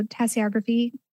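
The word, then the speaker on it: tasseography: I wanted to ask you about tassiography. Is that how you say it? And you had a connection with your nan through that. Is that right? – Yeah. So tassiography that's the right way to say it.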